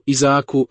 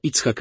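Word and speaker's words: Icchak (יצחק): Icchaku, Izaaku, Rabinie. W dzisiejszym Izraelu jest facet o imieniu Icchak Rabin. Icchak oznacza śmiech, a wy wymawiacie to Izaak, ale tak naprawdę to Icchak.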